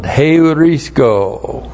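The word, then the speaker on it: heurisko (εὑρίσκω): That Abraham, our human forefather, and then we have, has found, heurisko. H-E-U-R-I-S-K, long O. Heurisko.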